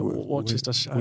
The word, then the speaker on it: Worcestershire: Worcestershire.